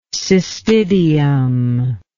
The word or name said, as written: cystidium